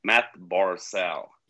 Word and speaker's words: Barzal: Och då var det ju en av våra följare på Twitter som sa att förstod inte varför vi uttalade Matt Barzal. Men då skrev du att ja, men det är så han uttalat sitt namn själv. Och så gick jag in på Elite Prospects där och pronunciation och spelade in en snutt Matt Barzal. Yep. Och så skickade jag till honom. Och då fick jag ju, oj då, det är så du uttalar.